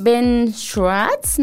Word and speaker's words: Ben Schwartz: Está también Ben Schwartz, me parece que se pronuncia.